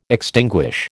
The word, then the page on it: extinguish: American English